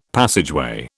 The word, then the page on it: passageway: British English